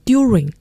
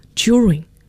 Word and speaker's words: during: 那么要注意这个单词的发音，很多同学在学英语的时候都把 during 读成 during, 但是要注意真正的地道发音是读成 during, 所以大家要注意这个单词的发音。